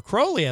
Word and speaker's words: Crowley: Adrian Crowley, Crowley, as I learned, is actually how I say his name in the second hour.